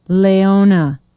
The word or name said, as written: Laona